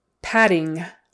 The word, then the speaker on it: padding: Two Ds, it's just a D sound in the middle. Padding. Again, that G on the end of the ing is not usually pronounced. In certain areas of the country, it might be, but typically mm, don't pronounce the G on the ing. Padding, padding, padding, a as in black, padding, ing, ing.